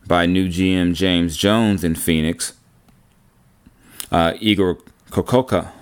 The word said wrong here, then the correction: Kokoskov: Oh, I'm sorry, I just butchered Igor's name. Igor Kokoskov.